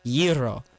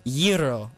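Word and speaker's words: gyro: just texted me and said it's pronounced Yiro. So when we're going to get our Mediterranean food, that's what we have to say. – Say it one more time. – Yiro.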